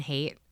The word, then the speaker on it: Haidt: If you've ever heard of Jonathan Haidt, it's because his last name is spelled H-A-I-D-T.